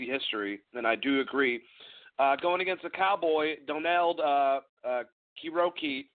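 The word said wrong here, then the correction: Cerrone: did I pronounce it right? Yeah, Kironi? Yeah, Kironi, I'm sorry. I'm terrible at doing this.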